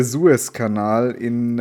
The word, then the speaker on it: Suezkanal: Wie, die, das, wie, wie spricht man es denn jetzt aus? – Es ist der Suezkanal in.